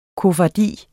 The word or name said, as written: koffardi